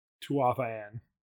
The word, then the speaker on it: Tuatha Dé'en: Tuatha Dé'en. I said it wrong the entire last episode.